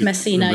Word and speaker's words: Messina: Messina? Is that how you say it? – It's Messina, yes.